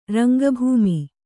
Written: ರಂಗಭೂಮಿ

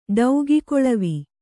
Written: ಡೌಗಿಕೊಳವಿ

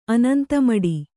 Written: ಅನಂತಮಡಿ

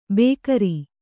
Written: ಬೇಗಾರಿ

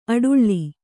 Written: ಅಡಳು

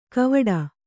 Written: ಕವಾಟ

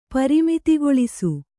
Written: ಪರಿಮಿತಿಗೊಳಿಸು

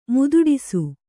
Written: ಮಾತಾಡಿಸು

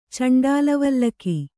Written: ಚಂಡಾಲವಲ್ಲಕಿ

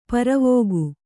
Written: ಪ್ರವೇಕ

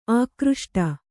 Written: ಆಕೃಷ್ಟ

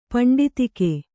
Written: ಪಿಂಡೀತಕ

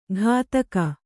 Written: ಘಾತಕ

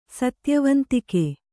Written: ಸತ್ಯವಂತಿಕೆ

